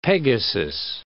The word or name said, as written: Pegasus